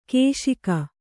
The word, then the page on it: ಘೋಷಕ: ghōṣaka